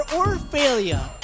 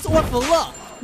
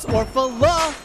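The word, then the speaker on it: Orfale: Delivery for Orphalia. Epic fail, bruh. The name is Orfala. Mr. Orphalee, can I see some ID? It's Orphala, cause you don't pronounce the E.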